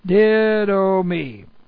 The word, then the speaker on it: didomi: Okay, this time we have the present active indicative of didomi. D-I-D long O-M-I. Didomi.